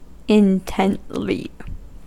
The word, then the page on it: intently: American English